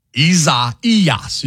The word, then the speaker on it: Isaias: Isaias, you know, that the accent is on the third syllable.